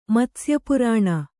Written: ಮತ್ಸ್ಯಪುರಾಣ

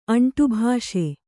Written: ಅಂಟುಭಾಷೆ

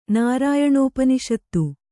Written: ನಾರಾಯಣೋಪನಿಷತ್ತು

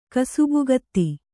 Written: ಕಸುಬುಗತ್ತಿ

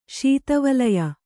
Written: ಶೀತವಲಯ

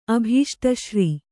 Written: ಅಭೀಷ್ಟಶ್ರೀ

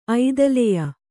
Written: ಐದಲೆಯ

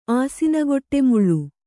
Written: ಆಸಿನಗೊಟ್ಟೆಮುಳ್ಳು

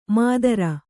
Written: ಮಾತುರ

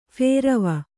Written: ಫೇರವ

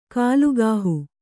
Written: ಕುಲಗೇಹ

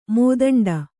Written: ಮೋದಂಡ

